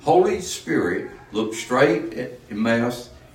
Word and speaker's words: Elymas: But E-L-Y-M-A-S. Pronounce it. – Elymas.